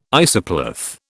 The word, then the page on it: isopleth: British English